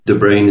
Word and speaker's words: De Bruyn: Yep, DeBrain is correct.